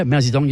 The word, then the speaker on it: Mao Zedong: Also, this day in history in 1949, we've got Mao Zedong. Is that how you say it? – Yeah, Mao Zedong, yep.